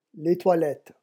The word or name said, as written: les toilettes